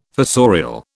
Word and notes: fossorial: British English

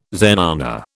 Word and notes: zenana: British English

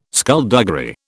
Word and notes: skulduggery: British English

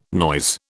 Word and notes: noise: British English

